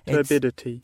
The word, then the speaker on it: turbidity: Turbidity.